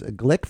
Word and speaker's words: Gleick: And while it's pronounced Gleick, it's spelled G-L-E-I-C-K.